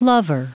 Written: lover

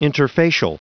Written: interfacial

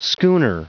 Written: schooner

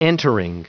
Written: entering

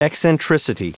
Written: eccentricity

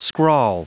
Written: scrawl

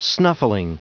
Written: snuffling

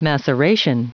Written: maceration

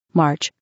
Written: march

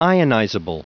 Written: ionizable

